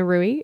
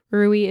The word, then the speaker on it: Ruwe: My name is Hannah Rui. It is spelled H-A-N-N-A-H. Rui is spelled R-U-W-E.